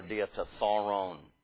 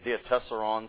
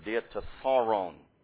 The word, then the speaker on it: diatessaron: An intriguing study, an edifying consideration of the glorious and ever-blessed person of Christ incarnate by means of our diatessaron. I know that diatessaron sounds more correct to you, but we're using the Greek pronunciation of such a word. It is a Greek word, diatessaron.